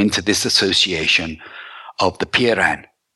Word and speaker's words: Peiran: In the Ten Laws of Solon, the third law is to create an association of Peiran I'll spell that for you, Mel. P E I R A N.